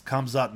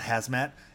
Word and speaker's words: Khamzat: Khamzat Chimaev is pronounced Khamzat, not Hazmat, and Jamal Hill is just Jamal.